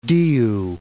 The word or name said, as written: Diu